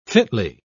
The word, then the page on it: fitly: British English